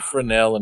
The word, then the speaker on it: Fresnel: Can't say Fresnel and make it all better. – Yeah, sure I can. – You and your silent S's.